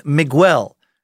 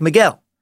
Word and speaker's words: Miguel: It's not Miguel, like how you've been pronouncing it. It's more like Miguel.